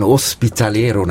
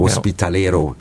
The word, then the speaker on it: Hospitalero: Sie sind das, was man ein Hospitalero nennt. Habe ich es richtig ausgesprochen? – Richtig, ja. – Hospitalero, Spanisch.